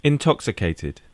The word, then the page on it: intoxicated: British English